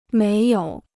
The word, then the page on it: 没有: méi yǒu